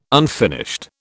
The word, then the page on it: unfinished: British English